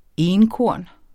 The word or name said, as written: enkorn